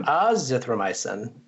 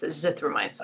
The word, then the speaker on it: azithromycin: And one pro tip is if you see a drug name and you're having a hard time pronouncing it, the emphasis almost always goes on the second syllable, which can help you pronounce it, sound it out. – So it's not azithromycin. – It's azithromycin. – Azithromycin.